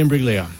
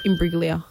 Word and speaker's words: Imbruglia: Natalie Imbruglia. Put it down. I'll give you that. – Soft G. – Imbruglia.